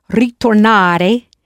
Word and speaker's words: ritornare: If you pronounced ritornare, you did well, since in Italian, this word is spelled with R-I, not R-E, as in English.